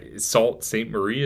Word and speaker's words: Sault Ste. Marie: So just curious, from like where you were staying in Sault Ste. Marie, is that?